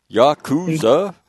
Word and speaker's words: Yakuza: Notice my really good pronunciation of that. I did not say that like I'm from Abilene, Kansas. Yakuza.